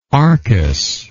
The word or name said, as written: arcus